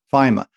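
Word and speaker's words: Faema: Is this mechanism from 1961, which was actually I realized then, and I'm so glad you pronounced it FEMA because I wasn't really sure. Is it FEMA? – FEMA.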